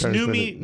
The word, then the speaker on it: Noomi: Is it Noomi or is it Naomi? – I think it's Noomi.